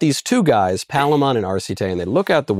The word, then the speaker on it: Arcite: and it's Palamon and Arcite. Mm-hmm. Is that how you pronounce it? Arcite? – Arcite.